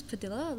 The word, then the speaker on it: Padilla: Yeah, it's two pronunciations. – Oh, wow. – In English, it's Padilla, like tortilla, but you'd say tortilla, right? – Yeah.